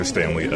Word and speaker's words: Stanley: Okay, my name is Dr. Stanley L. S-T-A-N-L-E-Y.